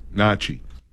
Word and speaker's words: gnocchi: Creamy pesto gnocchi bowl. – Gnocchi. – G-N-O-C-C-H-I.